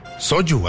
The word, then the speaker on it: soju: ¿Soju? ¿Así se pronuncia? – Así se pronuncia, soju.